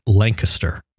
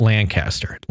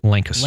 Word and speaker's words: Lancaster: I went to and I have to say this correctly because I learned it, Lancaster, Pennsylvania. Not Lancaster, Lancaster.